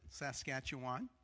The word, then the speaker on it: Saskatchewan: He's from Regina, and that's the way Canadians pronounce it, Saskatchewan.